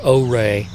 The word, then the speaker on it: Ouray: Silverton, Colorado, a mining town, Ouray or Ouray, which is how it's pronounced.